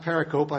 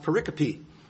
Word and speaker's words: pericope: If you start, and we'll get into commentary use later on, but it's actually not pronounced pericope. I found it's pronounced pericope.